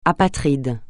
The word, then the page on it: apatride: francais